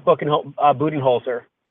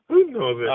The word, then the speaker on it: Budenholzer: You know, this is a team that played, you know, Mike Budenholzer, who is the head coach. Yes, I did pronounce that correctly. – I laugh every time I hear it. – Budenholzer? – Budenholzer.